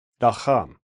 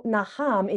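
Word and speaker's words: naham (נחם): With the stress naham. On the second syllable, naham. – Perfect. Okay. So naham in Hebrew, here is what it means.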